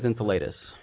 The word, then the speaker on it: Enceladus: I've heard it pronounced a couple of different ways. The way I pronounce it is Enceladus.